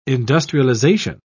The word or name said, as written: industrialization